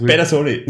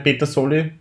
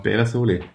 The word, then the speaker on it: Pedersoli: So Pedersoli, so wie Peter. So wie Petersü. – Ja, hm. ja hast, Super. Sagt, spricht man so aus. Voll. Ja, ne, Pedersoli. Also nicht Petersoli. Nein, Beda. Beda.